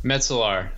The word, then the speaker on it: Metzler: Metzler. It rhymes with the mnemonic I give is it rhymes with pretzel jar.